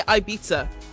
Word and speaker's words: Ibiza: How do you say it? Because we say Ibiza. – But I just say Ibiza.